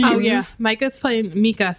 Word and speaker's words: Micah: The name is Micah. Like, if somebody's name is Micah, it's spelled exactly the same way. And his is just pronounced Mika for some reason.